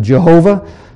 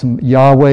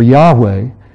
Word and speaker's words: Yahweh: Some pronounce it Jehovah. Most likely, that's not the correct pronunciation. Jehovah is a name that was came up as the result of a someone who was transcribing the scriptures in the 1500s. Some think that is perhaps Yahweh is the way it should be pronounced.